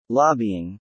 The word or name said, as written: lobbying